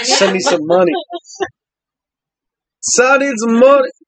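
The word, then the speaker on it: Sinéad: So, if I was looking at this, I would say, like, how it says you're not supposed to say it. Sinead. But it's Sinead.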